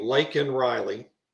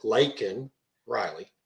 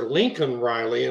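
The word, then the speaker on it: Laken Riley: With her name written on it, Lakin Riley. – Right. – And he can't even pronounce her name. He calls her Lincoln Riley.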